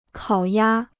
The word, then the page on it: 烤鸭: kǎo yā